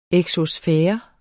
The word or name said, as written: exosfære